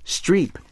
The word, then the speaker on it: Streib: Mm-hmm, but it turns out when we did her genealogy, we traced her back to her eighth great-grandfather, Johann Jakob Streib, which is pronounced Streep in German, who was born in 1607 in Lofenau, Germany.